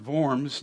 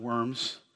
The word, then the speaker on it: Worms: But it's pronounced Diet, and of course it's German Worms, not Worms.